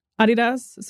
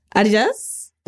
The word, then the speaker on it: Adidas: Adidas. So I could. – Adidas? – Well, that's how you say it outside of the U.S.